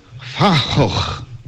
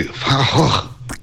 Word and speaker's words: Van Gogh: Fahoch sarebbe Van Gogh, ma loro lo pronunciano così, Fahoch.